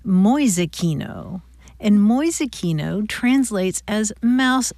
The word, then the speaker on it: Mäusekino: M-A-U-S-E-K-I-N-O, Mäusekino.